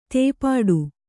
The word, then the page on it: ತೇಪಾಡು: tēpāḍu; Kannada